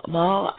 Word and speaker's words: Mal: Now, just to give you a little bit more about my guest, Mal Duane. And Mal, I am pronouncing it properly, I hope. – Well, very close. You can say Mal or Mal.